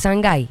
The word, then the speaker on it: Sangay: Eh, creo que es Sangay, no Sangay. No, no, no, Sangay. – Es Sangay, sí, por aquello de la pronunciación. Sangay. – Muchos quisieran decirle Sangay. – Sí, pero no es Sangay.